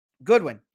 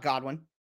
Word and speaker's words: Goodwin: But Goodwin, yes. Yeah, not Godwin.